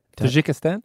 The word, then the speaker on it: Tajikistan: Tajikistan? – Yeah.